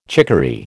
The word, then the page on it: chicory: American English